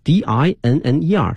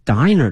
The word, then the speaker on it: dinner: dinner 呢是两个 n d i n n e r，它的发音呢是 dinner。如果说少了一个 n，就一个 n 的话呢，它是一个 diner 的发音哈。